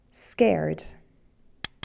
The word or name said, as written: scared